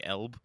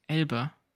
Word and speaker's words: Elbe: No, B is the E. Because without the sound, it would just be Elb. – Yes. – Elbe. – Elb. – No, not Elb. It's two syllables.